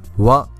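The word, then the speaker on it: wa (は): Donc wa ici, on le prononce comme si c'était un W, donc W-A, alors que normalement ce hiragana devrait être prononcé ha. Alors là c'est un cas spécial, quand ce hiragana est utilisé en tant que la particule, comme ça une particule, donc wa, il faut la prononcer wa et non pas ha.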